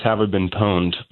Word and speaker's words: Have I Been Pwned: Yes, I believe it's pronounced "Have I Been Pwned," but I have a family member who works at a pawn shop, and I saw PWN, and I thought pawn.